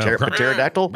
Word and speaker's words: Pterodactyl: Pterodactyl? How do you say it? Pterodactyl? – Pterodactyl is correct. Pterodactyl.